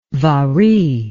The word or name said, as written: vari